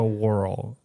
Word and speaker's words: whorl: Referring to a whorl. – Whorl. – W-H-O-R-L, whorl. Well, yeah, that's exactly how you're saying it.